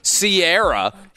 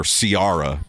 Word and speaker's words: Ciara: Well, what happened is when she got with Russell Wilson and sports people started talking about her, guys that didn't know music were calling her Ciara because of the way it's spelled.